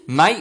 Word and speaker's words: mate: Mate or Mite? – Mate. – Mate. – Like M-A-T-E.